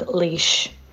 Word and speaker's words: Laois: I need to correct you on your pronunciations. It's Sligo, Mayo, and Galway. Then Armagh, Tyrone, and Offaly. And then Leitrim, Donegal, and Laois.